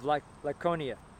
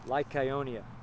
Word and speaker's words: Lycaonia: They were aware of it and fled into Lystra and Derbe, cities of Lycaonia. That's how you pronounce it. Lycaonia.